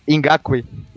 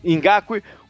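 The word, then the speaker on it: Ngakoue: Como é que fala o nome do, do rapaz? Você sabe que eu sou ruim nessas coisas, né? Ingakui. Ingakui, o Fowler e o Ingakui mais, né, mais, mais aberto, os quatro vindo no, no, no pass rush.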